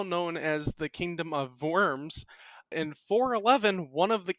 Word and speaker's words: Worms: Is that how you say it? It's spelt Worms. – Worms. – Worms? Oh, I should have known the V.